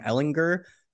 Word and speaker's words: Ehlinger: I think it's a hard G, actually, Sam Ehlinger.